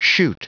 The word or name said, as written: chute